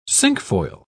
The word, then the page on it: cinquefoil: British English